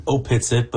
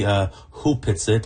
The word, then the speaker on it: Opetsit: It's written Opetsit, but it's actually pronounced properly Hupetsit. And the root word is Hupath in the Nuchanath language.